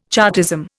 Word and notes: Chartism: Indian

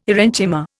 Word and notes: aerenchyma: Indian

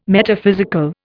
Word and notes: Metaphysical: Indian

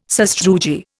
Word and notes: sastrugi: Indian

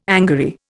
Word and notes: angary: Indian

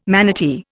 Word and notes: manatee: Indian

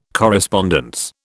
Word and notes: correspondence: British English

